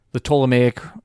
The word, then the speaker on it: Ptolemaic: Words containing a silent P. – Pterodactyl. Can I also say pteranodon? – No. – psychologist, psychiatrist. That's three. The Ptolemaic people.